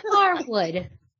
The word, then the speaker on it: Harwood: It's Dax Harwood. There is no D. – Harwood. Huh. – Good to know. – I learned something new today. Harwood. Dax Harwood. – No D. No D.